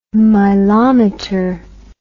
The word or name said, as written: milometer